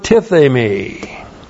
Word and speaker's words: tithemi: We have the verb tithemi. Looks like that in the Greek, T-I-T-H, long E-M-I, tithemi.